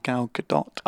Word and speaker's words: Gal Gadot: Apparently you pronounce her name Gal Gadot.